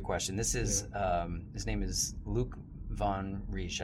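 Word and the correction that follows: Van Parijs: I think. – Von Paris. – Von Paris, yeah. I'm sorry, pronounced that wrong.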